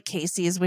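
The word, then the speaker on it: Caissie: My name is not spelled like Casey's, which is the normal way to spell it.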